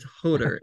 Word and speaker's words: Joder: J-O-D-E-R, but it's Hoder is how you say it, though.